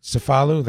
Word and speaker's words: Cefalù: I was curious about, you call it Cefalù, that's how you say it? – Yes.